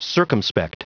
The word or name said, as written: circumspect